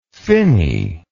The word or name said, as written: finney